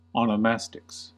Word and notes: onomastics: American English

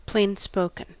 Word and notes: plainspoken: American English

